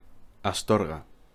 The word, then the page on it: Astorga: Spanish